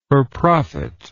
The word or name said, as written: for profit